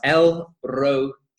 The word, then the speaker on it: El Roi: It is pronounced El Roi, Roi, and you say that quickly, so it's El Roi, El Roi.